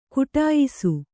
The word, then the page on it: ಘಟಿಯಿಸು: ghaṭiyisu